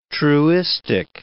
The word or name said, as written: truistic